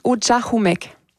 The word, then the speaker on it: Ujahumek: On le prononce « Ujahumek ».